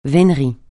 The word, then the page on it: vénerie: francais